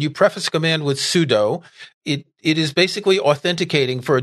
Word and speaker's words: sudo: And by the way, most people that I know say sudo, but officially the pronunciation is sudo, which is why I try to say it that way.